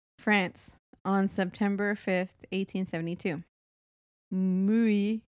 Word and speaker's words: Muy: M-U-Y. All right, so the vampire of Muy. I'm not joking. – Like vampire of Muy. – No, it's Muy. – Muy. That's how you pronounce it, because it's French.